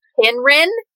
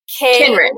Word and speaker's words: Kynren: So it starts with a C, but it's a hard C. It's Kinren. – Kinren. – Yep. K-Y-N-R-E-N.